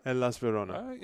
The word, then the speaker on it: Hellas Verona: If you haven't heard, AJ Soares reportedly heading to Hellas Verona. Did I say that right? – Hellas Verona.